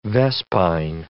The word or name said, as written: vespine